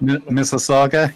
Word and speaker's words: Mississauga: Mississauga, yeah.